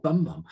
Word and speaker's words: Bumbum: He said, yeah, B-U-M-B-U-M, like the bum.